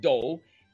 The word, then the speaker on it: Doagh: He was born in a town called Doe, pronounced Doe, D-O-A-G-H, in County Antrim.